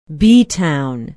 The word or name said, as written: beetown